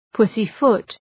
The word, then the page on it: pussyfoot: ‘pʋsı,fʋt